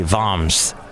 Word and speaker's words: Worms: It was in the town of, it's spelt like Worms, but I think they say Vams.